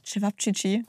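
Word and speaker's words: Ćevapčići: Wie sprichst du es aus? Chivapchichi. – Okay, du hast es richtig ausgesprochen.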